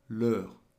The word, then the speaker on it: leur: Leur. – Leur is spelt L-E-U-R.